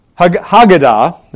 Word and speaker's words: Haggadah: which were found in the rabbinic Haggadah, that's how it's pronounced, which were something which is recited, I believe, even today by Jews at Passover.